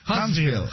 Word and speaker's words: Huntsville: ¿Huntsville? ¿City? ¿Sí lo dije bien, o no? – Sí, Huntsville. – Huntsville, City.